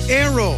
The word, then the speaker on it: Naoero: This program is previously called the country Nauru, N-A-U-R-U. The 11,000 residents have altered Nauru to be now Aero, N-A-O-E-R-O. – They're going back to the pronunciation in their native language and losing a pronunciation used by outsiders.